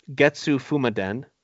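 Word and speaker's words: Getsu Fuma Den: And I'm gonna butcher this pronunciation, but it's Getsu Fumaden. – Yeah, I think that's right.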